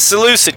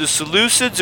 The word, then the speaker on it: Seleucid: And it's Seleucid, because you know on every show I'm like, was it the Seleucids or the Ptolemies? It's Seleucid. Seleucid dynasty that rose up after Alexander the Great died.